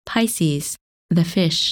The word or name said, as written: Pisces